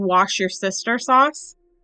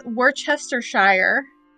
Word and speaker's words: Worcestershire: Because this woman was explaining the sauce, and she said, wash your sister sauce. Instead of Worcestershire. – Is that how you say it? – Yes. – Okay. – She said, wash your sister sauce. – I mean, like, do you do? – Fucking I always say Worcestershire, so. – But for those, it's Worcestershire.